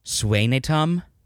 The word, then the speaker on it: Skanicum: It's spelled Scanicum, like S-K-A-N-I-C-U-M, but it's pronounced Swanatum.